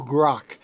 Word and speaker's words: grok: They call it grokking, or to grok, and that's to assimilate into every subatomic particle of your being. – Okay, it's grok? – Grok. To grok. – How do you spell it? – I think it's G-R-O-K, or maybe C-K.